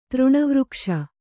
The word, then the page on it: ತೃಣವೃಕ್ಷ: kannada